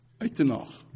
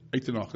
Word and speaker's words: Uitenhage: Uitenhage, to be quite accurate. And that's the correct pronunciation. Uitenhage, that's the Afrikaans pronunciation.